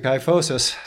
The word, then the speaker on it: kyphosis: I can see the kyphosis. God on my case, I'm sure I mispronounced that, but um, no, that was right on. Perfect.